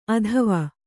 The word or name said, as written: ಅತ್ವ